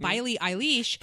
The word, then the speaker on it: Billie Eilish: I read that apparently Billie Eilish, that's how you say it, Billie Eilish.